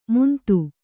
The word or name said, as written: ಮುಂದು